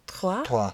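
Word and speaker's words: Troyes: Trois.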